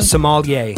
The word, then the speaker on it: sommelier: Sommelier.